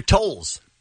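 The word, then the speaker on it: Toles: We've got your tickets for the K-Rock Weenie Roast E-Fiesta, and we've got Los Angeles Dodger Andrew Totes. – Did you say that right? – Andrew Tolles. It's his name.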